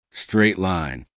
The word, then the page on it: straight line: American English